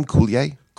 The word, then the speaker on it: Coulier: Coulier.